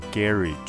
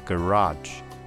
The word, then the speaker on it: garage: 那英式跟美式在发这个字的发音有点不一样，英式叫做 garage，美式叫做 garage。